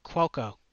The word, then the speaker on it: Cuoco: The nominees are Andy Allo as Nora in Upload, Catherine O'Hara as Moira Rose in Schitt's Creek, Kaylee Cuoco. – Is that how you say it? – Yes, I learned that today.